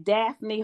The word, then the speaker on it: Daffney: And also, um, they can reach me personally at Daffney Hawkins' Facebook page.